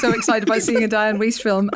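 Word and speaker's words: Dianne Wiest: Diane Wiest. I said Diane Feast when I interviewed Rosamund Pike. I've never pronounced it that way before. I just panicked and went with a Diane Feast.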